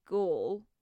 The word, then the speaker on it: gall: to gall. That's how you say it, right? – It is.